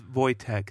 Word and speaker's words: Wojtek: Oh, it's Wojtek. That's how you pronounce it.